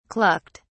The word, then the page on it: clucked: British English